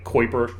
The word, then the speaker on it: Kuiper: Most people call it the Kuiper Belt, but it's actually pronounced like Kuiper.